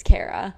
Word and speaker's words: Ceara: So it's Irish. So it's spelled C-E-A-R-A, mm-hmm. which looks like Sierra, obviously, but it's Kara.